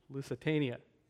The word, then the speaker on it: Lusitania: In the early part of 1915, there was a passenger ship called the Lusitania. I'm not sure how you pronounce it. Somebody tell me. Lusitania.